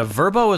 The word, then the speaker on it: Vrbo: The Vrbo? Is that how you pronounce it? – Yeah, Vrbo is kind of like Airbnb for, I think it's the same type of deal for condos and things like that.